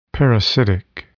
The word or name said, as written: parasitic